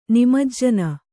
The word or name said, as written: ನಿಮಜ್ಜನ